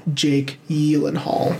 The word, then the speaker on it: Gyllenhaal: Jake Gyllenhaal. – Is that how you really pronounce his name? – It is.